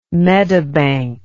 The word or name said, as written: medibank